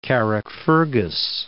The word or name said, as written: Carrickfergus